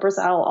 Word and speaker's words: Brizel: So Just Marla Brizel all over the internet.